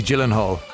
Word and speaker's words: Gyllenhaal: Taylor Swift, seemingly a song written about Jake Soft G Gyllenhaal.